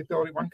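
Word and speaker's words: AbilityOne: GSA, along with 10 other agencies, by statute, has a member of the Ability One Commission.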